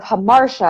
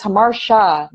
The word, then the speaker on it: Hamartia: She was like, it's Hamartia, not Tia.